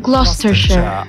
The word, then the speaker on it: Gloucestershire: In Gloucestershire. Ich liebe diesen Ort, der wird so geil ausgesprochen. – Gloucestershire.